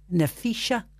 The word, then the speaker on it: Naphisa: Now, it's spelled N-A-P-H-I-S-A. Nafrisha.